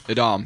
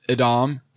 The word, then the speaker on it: Edam: And he said, it's Edam and it's made backwards.